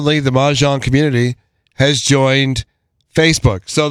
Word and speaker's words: Mahjong: By the way, we were pronouncing Mahjong correctly.